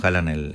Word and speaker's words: Haaland: Venía con Haaland el noruego. – Y que también se dice así, en realidad. – Sí, se dice así, bueno.